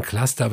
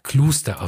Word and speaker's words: Cluster: Äh, die Leute sagen Cluster, aber ich musste mich von einem ehemaligen Mitglied der Band belehren lassen, dass der Name Cluster ausgesprochen wird, also eine Elektronik-Krautband äh, aus den 70ern.